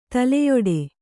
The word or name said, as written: ತಲೆಯುಡೆ